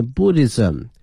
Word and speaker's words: Buddhism: 个历史、啊、同时呢我们通过这个石刻能够了解到古代、啊、老百姓的一个日常的生活同时如果你对佛教道教和儒教感兴趣的话呢这些雕刻是必看的这个景点 If you are interested in Buddhism, Taoism and Confucianism 三个教哈，一个是佛教道教和儒教哈。那么第一个呢叫佛教英文的拼写呢是 B-U-D-D-H-I-S-M它的发音是 Buddhism 道教呢是 T-A-O-I-S-M T-A-O-I-S-M 是 Taoism 儒教哈叫 Confucianism 它的拼写是 C-O-N-F-U-C-I-A-N-I-S-M